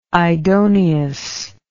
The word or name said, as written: idoneous